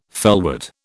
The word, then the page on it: felwort: British English